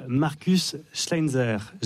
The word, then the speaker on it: Markus Schleinzer: Le film s'appelle donc La vie d'une femme de Charline Bourgeois-Taquet et votre autre conseil de la semaine, c'est Rose de Markus Schleinzer.